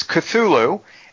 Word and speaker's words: Cthulhu: It's Cthulhu, and the the underwater city that fortunately hasn't risen is isn't it Rolia? – I think it's Grier.